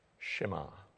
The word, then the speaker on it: shema: In the Hebrew language in which this was both spoken and written 3,500 years ago, the word for hear or listen is a Hebrew word pronounced in English, shema.